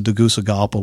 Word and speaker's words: Tegucigalpa: We flew into Tegucigalpa, which is an adventure all in and of itself.